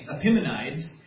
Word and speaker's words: Epimenides: Greek poet by the name of, there's different ways to pronounce it, but I'll pronounce it Epimenides.